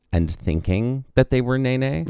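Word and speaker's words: Nēnē: And it is pronounced Nene.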